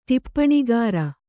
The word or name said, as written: ಟಿಪ್ಪಣಿಗಾರ